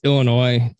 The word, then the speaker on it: Illinois: Yes, it is Illinois. The S is silent.